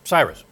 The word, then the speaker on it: Cyrus: Am I saying it right? Cyrus. Yes, Cyrus.